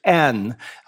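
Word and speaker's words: en (ἐν): The word there is, the preposition in the Greek is en. We would spell it en or epsilon nu if you want to be fancy. It just simply means in. So en sounds like in, so it makes it an easy one to remember.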